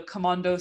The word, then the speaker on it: Kamondo: So the stairs are called that Osman and Edif are eating their kumpir on are called the Komando stairs, I guess, with the Turkish pronunciation.